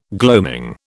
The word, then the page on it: gloaming: British English